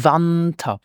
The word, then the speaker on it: Winter: Now, the Winter starts with the letter W, and this is pronounced like a V in English, like in view. So we say Winter, the Winter.